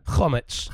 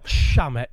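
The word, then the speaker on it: chametz: Chometz. – Chometz. There you are. – Shametz. Absolute philistine.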